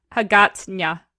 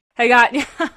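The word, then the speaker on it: Hagåtña: Okay, it's spelled Haga with a little dot over it. TN with a tilde. I know what a tilde is because I took Spanish. TN tilde A. Hagatnya. Yeah. Like manana.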